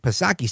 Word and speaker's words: Psaki: Looks like Jen Psaki, silent P, of course.